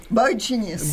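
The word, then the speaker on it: boygenius: Beau génius. Boy Genius. – Boy, yes. – boy, boy, Genius. Voilà, donc il faut bien le prononcer, excusez-moi.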